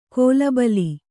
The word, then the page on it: ಕೋಲಬಲಿ: kōlabali